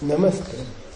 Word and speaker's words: Namaste: And is Nama with a long A or a short A? Do you say Namaste or do you say Namaste? And is that name? Hmm? It's long? – I think so. – Do you say Namaste? – It's Namaste.